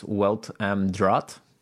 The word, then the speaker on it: Welt am Draht: The title is actually pronounced Welt am Draht, or something like that.